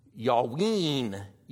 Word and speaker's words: Javan: And Javan is, the way you would pronounce that would be Yawin, Yawin, which is going to turn out to be Ionia.